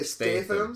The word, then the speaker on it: Statham: I say Statham. – Statham. – I say Stratham. – I don't even think there's an R in his name. – No, there isn't. – No research. – Jason Statham.